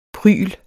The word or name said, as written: prygl